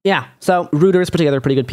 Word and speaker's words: Reuters: Oh no, it's Reuters.